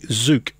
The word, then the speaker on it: Tzuke: So the song was Enjoy the Ride, and it was by Judy Zook. And if you've got a pen there, Frank, it is spelt T-Z-U-K-E.